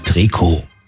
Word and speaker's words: Trikot: Und weil im Französischen in der Regel die letzte Silbe betont wird, heißt es richtig Trikot.